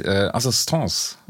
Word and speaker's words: Assistance: Jetzt haben Sie in Ihrer Studie festgestellt, und ich muss zugeben, ich habe es immer falsch ausgesprochen, Assistance, sagen Sie, ne? Mhm. Okay.